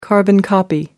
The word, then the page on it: carbon-copy: American English